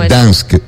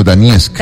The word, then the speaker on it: Donetsk: No se dice Dansk, sino Donetsk.